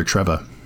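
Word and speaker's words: Trevoh: Yeah, that's his younger brother, Trevor. – Oh, Trevor. Okay. – Well, so, Trevor, yeah. Or Trevo. It's T-R-E-V-O-H. So it's a different pronunciation but.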